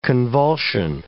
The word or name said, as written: convulsion